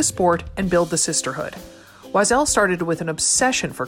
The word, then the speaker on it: Oiselle: It's pronounced Wazelle and it's spelled O-I-S-E-L-L-E. It's French for bird.